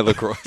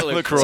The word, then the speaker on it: LaCroix: Yeah, my LaCroix. Sorry, my LaCroix. Sorry, I pronounced that word wrong.